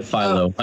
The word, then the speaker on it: phyllo: And today I learned that that word is properly pronounced phyllo. I've called it phyllo my entire life, but it is correct.